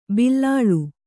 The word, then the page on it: ಬಿಲ್ಲಾಳ್: kannada